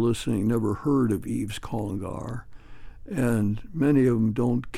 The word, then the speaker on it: Congar: How'd I do with the pronunciation of his last name? – I think you did pretty good. – Congar. – Yeah, I think that's pretty much it.